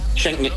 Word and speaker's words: Schengen: Schengen.